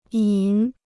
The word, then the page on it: 瘾: yǐn